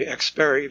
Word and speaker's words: Xperi: HD radio developer and licensing company Xperi, that's spelled X-P-E-R-I,